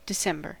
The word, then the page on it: December: English